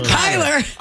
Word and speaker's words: Kyler: If Tyler Murray, Kyler.